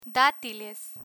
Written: datiles

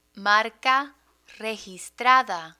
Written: marca registrada

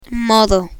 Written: modo

